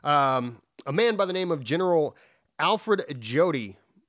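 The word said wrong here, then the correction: Jodl: or Jodi. – Yodel. – Yodel. – Okay. Yodel. The J is silent. – Yeah, that's an L, not an I. Yodel.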